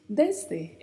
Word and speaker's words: desde: Falamos desde, desde, é o E mais fechado e o S com um som bem marcado de S mesmo.